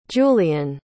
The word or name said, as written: jullien